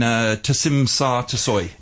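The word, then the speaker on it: Tsim Sha Tsui: In Tasim Sar Tasoi.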